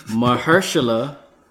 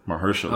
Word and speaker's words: Mahershala: Did this dude, Marshall, how you say his name? Marshawn Lynch? – Jesus. Mahershala. – Mahershala.